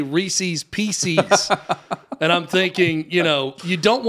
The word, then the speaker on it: Reese's Pieces: It is Reese's Pieces.